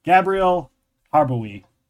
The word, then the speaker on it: Gabrielle Harbowy: Please welcome Gabrielle Harbowy.